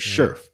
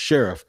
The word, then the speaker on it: Scherff: So Brandon Scherff, and I used to say mm. Sheriff, but it is pronounced Sheriff, the all pro guard from Washington that they signed to solidify the right guard position.